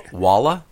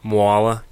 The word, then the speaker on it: Mwala: Walla? – Mwala, kind of like koala, except with an M.